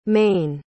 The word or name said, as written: maine